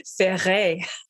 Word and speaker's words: Ferré: And I love your name, Ferré, but how do you say it with a French pronunciation? – Ferré.